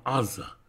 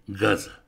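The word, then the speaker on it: аза (עזה): Поэтому на современном иврите сектор «газа» мы называем сектором «аза», а не «газа». Точно так же и Аталия стала «гафолей».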